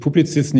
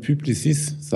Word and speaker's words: Publicis: von Ihnen gelernt, dass, dass ich Publizist nicht so aussprechen sollte, sondern Publicis sagen sollte.